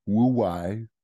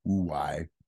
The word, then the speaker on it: Huawei: of decade-long cyber espionage campaign against Huawei servers, right? I'm probably saying that wrong. H-U-A-W-E-I. Huawei.